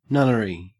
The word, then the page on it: nunnery: Australian English